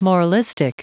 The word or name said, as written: moralistic